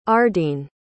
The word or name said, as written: ardene